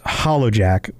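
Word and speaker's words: Hollowjack: The actual name is Hollugh, which is Hallugh-Zhek. But it's generally pronounced Hollow Jack or Lord Hollow Jack.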